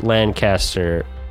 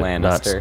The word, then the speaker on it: Lannister: Yeah, Tyrion, like Lancaster, but Lannister.